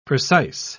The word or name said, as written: precise